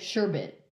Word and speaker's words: sherbet: Because it's like you're supposed to say like, not like sorbet, but it's supposed to be like sherbet, which is stupid.